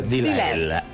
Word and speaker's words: Delilah: Sí, que se pronuncia Delilah.